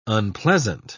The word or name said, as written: unpleasant